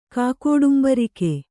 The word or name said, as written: ಕಾಕೋಡುಂಬರಿಕೆ